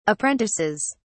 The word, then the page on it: apprentices: British English